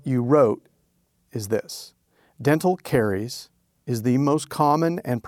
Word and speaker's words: caries: How do I pronounce it? Is it caries? – Mm-hmm.